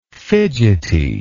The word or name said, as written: fidgetiness